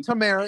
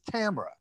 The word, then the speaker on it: Tamara: It's not Tamara, it's Tamara.